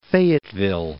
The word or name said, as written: Fayetteville